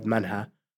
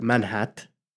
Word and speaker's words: Manhat: it give you the word manha. But now we are preferred to use the word manhat to pronounce the T instead of making the T silent.